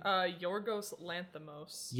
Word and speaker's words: Yorgos Lanthimos: Yorgos Lanthimos.